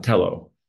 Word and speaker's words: Telo: I'm talking right now about Tello trucks, and it's spelled T-E-L-O, Tello.